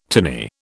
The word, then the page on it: tinny: British English